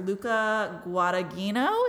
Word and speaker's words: Luca Guadagnino: But there was an interview with the director, Luca Guadagnino. Is that how you say it? – Yeah.